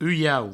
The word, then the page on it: ulhauc: uiau